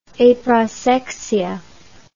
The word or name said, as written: aprosexia